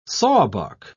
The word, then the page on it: sawbuck: British English